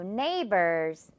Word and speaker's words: neighbors: 자, 그리고 GH 묵음 계속 신경 써주세요. One more time. They are our new neighbors. 네, 여러분. 정말 잘했어요. neighbor, 이웃이라는 뜻까지 잘 기억해 주시고요. 어때요, 여러분? GH, 뭔가 생긴 것도 어려운데 발음상 아예 생략이 된다고 하니까 반갑지 않나요?